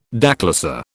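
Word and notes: déclassé: British English

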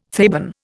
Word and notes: Theban: Indian

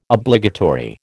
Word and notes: Obligatory: American English

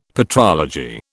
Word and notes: patrology: British English

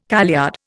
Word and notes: kaleyard: Indian

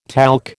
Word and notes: talc: American English

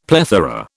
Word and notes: plethora: British English

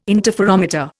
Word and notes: interferometer: Indian

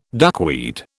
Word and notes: duckweed: British English

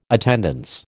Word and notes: attendance: American English

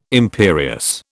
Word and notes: imperious: British English

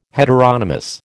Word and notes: heteronomous: American English

